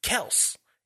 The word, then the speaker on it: Kelce: Went on social media sitting in his car and talked about how people have been mispronouncing his name for years. And he just never said anything. He was just letting it slide. He said that it was Kelce.